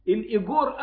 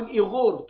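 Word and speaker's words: الأويغور: الايجور او الايغور تنطق هكذا وهكذا،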